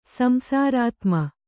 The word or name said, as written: ಸಂಸಾರಾತ್ಮ